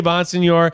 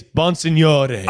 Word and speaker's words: Bonsignore: No, it's Bonsignor. So here's the thing. I say Bonsignor. It is Bonsignore. – Oh, so we're doing it right. – That's how you would say it. But I say Bonsignor because, like, people don't have time.